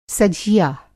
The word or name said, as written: सझिया